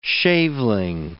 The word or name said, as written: shaveling